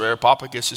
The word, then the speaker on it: Areopagus: And they took him and brought him to the Areopagus, or Areopagus, as some pronounce it, I see Areopagus, and saying, may we know what his new teaching is and what you are proclaiming.